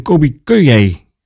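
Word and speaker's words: Colbie Caillat: Hoe spreek je het uit? Kobe Kayé of Kobe Kaya. Je moet eigenlijk zeggen Kobe Kayé, heb ik gelezen nu.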